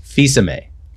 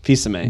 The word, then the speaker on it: Fils-Aimé: That's right. Reggie Fils-Aimé. – Fils-Aimé. – It's like a silent L, basically.